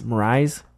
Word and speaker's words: Mraz: How do you say his name? – Mraz. – Yeah. Remember we talked about this. Mraz. – I fucking hate his name. – Like Mraz.